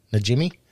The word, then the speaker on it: Najimy: Najimy.